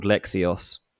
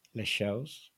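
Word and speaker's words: Leixões: Les Chaux. It's quite hard. Les Chaux. That's a really hard one because like the OE sound is tricky and then you have to try and swallow the S.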